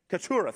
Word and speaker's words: Keturah: Hagar, Sarah, and Korah. Did I say that right? Keturah, thank you.